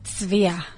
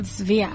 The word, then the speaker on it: Tzvia: So you spell it T-Z-V-I-A. – Yeah. – You pronounce it? – Zvia. – Okay, so you do hear... – Zvia. – Okay. – So like you do hear the T-Z-V.